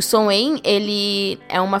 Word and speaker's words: Samhain: A roda do ano ela rege essa festividade e o Soen, né, que se escreve Samhain, seria a festividade localizada no dia 31 de outubro, que é o Dia das Bruxas.